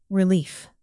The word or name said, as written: relief